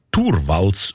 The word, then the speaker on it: Torvalds: Der Linus Torvalds, eigentlich Turwals ausgesprochen.